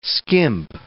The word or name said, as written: skimp